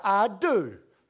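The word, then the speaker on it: I do (ἰδού): The Greek word for the word behold is a word spelled I-D-O-U. And it's pronounced I do.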